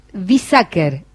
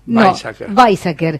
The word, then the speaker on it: Weizsäcker: Pero comento que es director del centro Weizsäcker, ¿lo dije bien? – No, Weizsäcker, de consulta médica, y del Instituto de Docencia e Investigación de la Fundación Luis Chiosa.